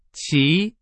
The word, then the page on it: 齐: Chinese